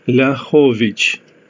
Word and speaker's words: Liachovic: Its correct spelling in the Lithuanian language is L-I-A-C-H-O-V-I-C. However, in order to pronounce it correctly, it should be spelled in English as L-I-A-H-O-V-I-C-H. It is pronounced as Ljahovic.